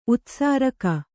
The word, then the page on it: ಉತ್ಸರ್ಗ: utsarga